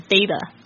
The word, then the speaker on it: data: 就是 T 介于两个元音之间呢它会就是浊化变成好像介于 T 跟 D 之间的发音，所以呢如果你们有兴趣的话可以再倒带听一次 David 老师他怎么念，他是念成 data 就是手机流量。